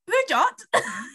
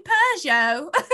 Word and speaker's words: Peugeot: Um, she was like, Pujot. she was like, You mean Peugeot?